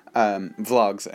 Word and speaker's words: vlog: Vlogs, I mean, not blogs. By the way, in case you can't tell from my pronunciation over the podcast, I am saying V-L-O-G, vlog, as in video log, not blog.